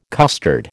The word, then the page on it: custard: American English